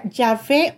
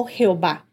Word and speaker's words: Yahweh: Cuando la vemos toda en letras mayúsculas, se refiere a Yahweh, que frecuentemente se pronuncia Yahvé o Jehová.